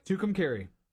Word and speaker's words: Tucumcari: Tucumcari.